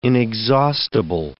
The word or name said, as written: inexhaustible